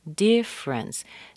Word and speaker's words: difference: Difference. Notice how we're missing a syllable here? Difference. D-I-F-F-E-R. The E is missing from that E-R.